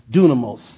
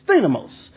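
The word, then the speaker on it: thinamos (δύναμις): If you're using Erasmus, most of us understand if I say dunamos. Amen. But we use the modern Greek pronunciation, so it's thinamos.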